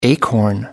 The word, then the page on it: acorn: American English